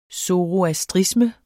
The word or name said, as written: zoroastrisme